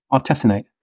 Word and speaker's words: artesunate: This first one is the safety and efficacy of artesunate. Is that how you say it? – Artesunate. – Thank you.